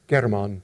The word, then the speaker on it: Hermann: And it's usually spelled with an H, but pronounced in Russian as German. And I'm trying to be excruciatingly correct here, tonight, so I will, that's the difference. In the handout I gave you, I spelled the name with an H, okay, but I'll be saying it with a G, hard G if I can remember.